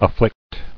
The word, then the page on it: Afflicter: af·flict; Af*flict"er